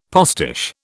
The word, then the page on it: postiche: British English